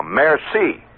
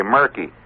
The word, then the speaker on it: merci: Or I'll say a merci. That's pretty good. I just learned last year that the C is pronounced that way. I used to say murky.